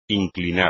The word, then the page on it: inclinar: espagnol